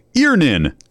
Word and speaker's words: Earnin: E-A-R-N-I-N is pronounced Earnin. I don't know how else you would pronounce it, but they really want to be clear that that's how to pronounce it.